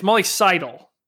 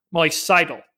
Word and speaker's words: Seidel: It's Molly Seidel. That's how you pronounce her name. Moving forward in 2021, she will be at the Olympics. Molly Seidel. That's the correct pronunciation.